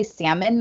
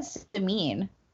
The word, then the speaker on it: Samin: Also, you say her name, you say Salmon Nosrat? – Salmon? I don't know. – I've always said Samin.